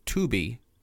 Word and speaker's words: Tubbee: So according to legends of the Choctaw Indians, a long time ago, Hamas Tubi, that is spelled T-U-B-B-E-E, who was a great Choctaw warrior, and his sons encountered a group of unusual beasts during their routine patrol through their territories.